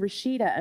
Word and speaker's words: Rashida: I mean, people would pronounce, I mean, Rashida, I mean, you look at it and you should be able to pronounce it, but people would pronounce my name incorrectly.